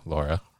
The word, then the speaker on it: LoRa: And now, so the big thing behind Semtech, right, is the LoRa technology, right, which is L-O-R-A. But I was told it's pronounced just like the lady's name, Laura, right? – Absolutely, yeah.